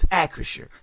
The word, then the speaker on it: Acrisure: Note, I finally got the name of the pronunciation of the stadium right. It's Acrisure.